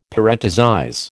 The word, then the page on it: parenthesize: American English